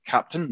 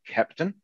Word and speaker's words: Kapton: Okay, so today we're going to talk a brief introduction about Kapton, pronounced Kapton, not Kepton. The reason it's called that is because that is the German phonetic pronunciation of captain,